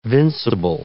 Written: vincible

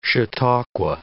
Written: chautauqua

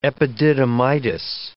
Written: epididymitis

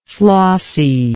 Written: flossiness

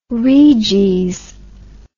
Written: reges

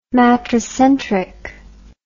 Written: matricentric

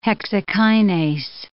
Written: hexokinase

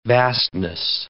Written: vastness

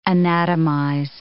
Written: anatomize

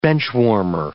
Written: benchwarmer

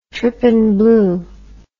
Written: trypan blue

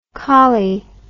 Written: coli

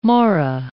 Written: mora

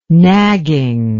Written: nagging

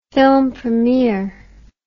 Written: film premiere

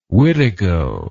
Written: witigo

